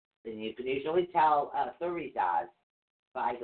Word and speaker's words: Thurisaz: This one is actually spelled T H U R I S A Z. But it is pronounced thurizaz. Thurizaz.